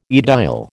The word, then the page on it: aedile: American English